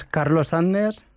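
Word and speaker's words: Carlos Sadness: es Carlos Sendes. A ver, ¿es así o no? sí, es Carlos Sandes.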